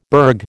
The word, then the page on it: burg: American English